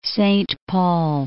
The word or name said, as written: Saint Paul